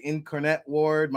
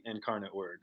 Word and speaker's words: Incarnate Word: And I hope I say it right, because my Jersey accent, I say Incarnate Ward. My Italian accent in me wants to say Incarnati Ward. I mean, give us the official pronunciation. – University of the Incarnate Word.